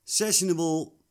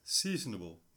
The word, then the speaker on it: sessionable: Sessionable Pale Ale. Seasonable. nee, sessionable.